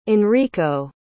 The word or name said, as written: enrico